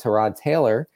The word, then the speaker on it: Tyrod Taylor: Tyrod Taylor, I said his name right for the first time.